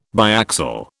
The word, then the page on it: biaxial: British English